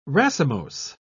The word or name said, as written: racemose